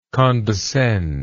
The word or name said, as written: condescendent